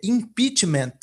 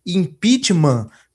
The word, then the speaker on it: impeachment: A pronúncia correta, a pronúncia ortodoxa é impeachment, com o T pronunciado. Né? Tem muita gente que fala impeachment como se fosse de origem francesa, mas na verdade o instituto ele é de origem inglesa, né?